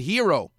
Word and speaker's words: gyro: But when you're Greek, you pronounce it hero, which is where the hero sandwich came from.